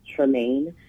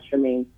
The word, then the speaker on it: Tremaine: I have my pages on Instagram at Genesis Tremaine. Tremaine spelled like the infamous Tremaine Hawkins.